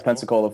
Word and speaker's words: Pensacola: So you're in Pensacola, Florida now. Did I say that right? – Pensacola, Florida. Yeah.